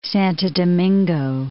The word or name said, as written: Santo Domingo